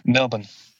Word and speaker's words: Melbourne: I was about to say you're supposed to say you need to say it without the R. Some Melbourne.